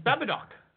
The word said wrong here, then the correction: Babadook: What? – Is that how you pronounce it? – No. Oh, my God, that's terrible. – How you pronounce it? – Babadook. Babadook, it's been evolving to Babakook.